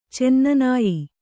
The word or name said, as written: ಚೆನ್ನನಾಯಿ